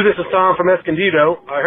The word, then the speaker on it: Escondido: Now, my man from Escondido, got to say that right, Escondido, he's calling about his favorite Raiders game and actually gives us two of them.